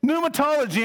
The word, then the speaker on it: Pneumatology: Pneumatology. Well, actually, the P is silent. Pneumatology, anyone? Holy Spirit.